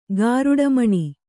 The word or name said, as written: ಗರುಡಿಮನೆ